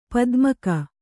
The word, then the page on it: ಪದಮಿಕ್ಕು: padamikku